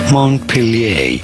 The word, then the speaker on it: Montpelier: Montpelier. – Montpelier.